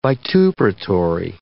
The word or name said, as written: vituperatory